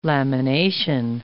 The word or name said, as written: lamination